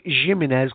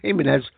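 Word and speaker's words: Jiménez: Some people pronounce it Jimenez because it's sort of German, but I think it's pronounced Jimenez.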